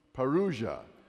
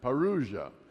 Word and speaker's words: parousia: Parousia. Are you familiar with that term? Parousia. Sometimes pronounced parousia. Parousia, but it's usually pronounced parousia.